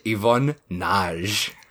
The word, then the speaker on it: Ivan Nagy: Directed by Yvonne Nage. – Wow, you really butchered that one, didn't you? – To my credit, it is his last name is spelled N-A-G-Y. – Yeah, I would have called him Nagy. – And then, having watched a whole lot of material about this guy, I'm like, oh, yep, it's Naj.